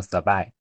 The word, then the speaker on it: Sabai: 它其实是一个拼读的读音，叫做 Sabai。